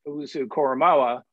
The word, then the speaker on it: Owusu-Koramoah: Greg Newsome, the cornerback from Notre Dame, from Northwestern, and then Jeremiah Owusu-Koramoah.